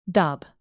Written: dob

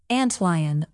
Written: antlion

